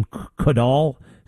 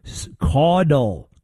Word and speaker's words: Caudle: How do you say this guy's name? Caudill? Caudill. Caudill. C-A-U-D-L-E.